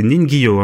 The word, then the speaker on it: Ningyo: Euh, donc c'est Ningyo, hein, je le dis bien, c'est ça.